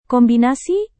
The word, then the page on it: kombinasi: indonesian